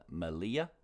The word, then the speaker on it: Melilla: It's M-E-L-I-L-L-A. But I believe it's part of Spain. So the double L makes a Y sound, not a L sound. So Malia.